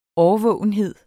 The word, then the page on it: årvågenhed: ˈɒːvɔˀwənˌheðˀ; ˈɒːvɔːwənˌheðˀ